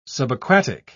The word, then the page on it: subaquatic: British English